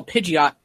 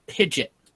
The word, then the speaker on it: Pidgeot: Because they call Pidgeot Pidget. – Pidget.